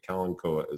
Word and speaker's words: Kalanchoe: Kalanchoe with the two dots over the E. I've heard it pronounced a variety of ways, but Kalanchoe is how I learned it.